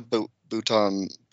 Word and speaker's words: Boughton: Felt, it was Louisa, what's her, Louisa, it's actually, is it Sarah Louisa Boughton. I say it like a crouton. – Crouton. – Boughton Felt.